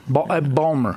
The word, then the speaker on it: Baltimore: Balmer. I believe they pronounce it. Balmer.